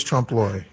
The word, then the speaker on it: Trompe l'oeil: Trompe l'oeil. – Yeah, Mike McCoy's Trompe l'oeil.